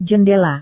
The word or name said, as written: jendela